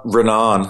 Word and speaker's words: Renan: Renan.